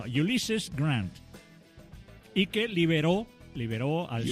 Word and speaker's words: Ulysses: Escuchad mi pronunciación, a ver si me... – A ver. – Ulysses. – Muy bien.